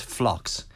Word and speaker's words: Phlox: The other nice herbaceous plant, and particularly the older listeners will know this plant, this is Phlox. – Oh. – Spelt with a P. – P-H-L-O-X. – Yes, Phlox.